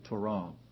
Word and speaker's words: Torah: Some pronounce it Torah. Most lexicons put the syllable on the accent on the second syllable, Torah.